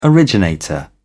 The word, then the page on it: originator: British English